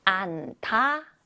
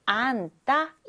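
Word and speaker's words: 앉다: 그 다음, 안타가 아니라 이 받침 뒤에 디귿은 쌍디귿 발음이 난다. 안따, 이렇게 읽어주어야 합니다.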